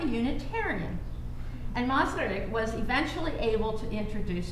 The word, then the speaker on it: Masaryk: The future president of Czechoslovakia, who was an acquaintance of Čapek's, Tomáš Masaryk, again, you'll have to forgive my pronunciation. – Mazurek.